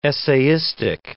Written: essayistic

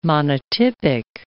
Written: monotypic

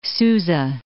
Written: Susa

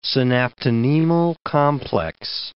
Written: synaptonemal complex

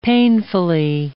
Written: painfully

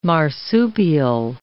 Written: marsupial

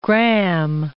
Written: gramme

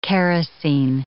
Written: kerosine

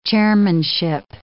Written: chairmanship

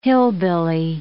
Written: hillbilly

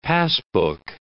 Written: passbook

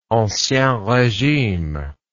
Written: ancien regime

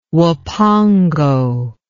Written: huapango

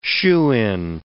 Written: shoo-in

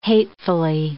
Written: hatefully

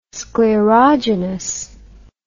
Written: sclerogenous

